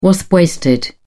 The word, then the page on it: wasp-waisted: British English